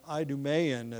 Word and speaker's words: Idumean: He was an Idumean, or an Idumean, as it's sometimes pronounced.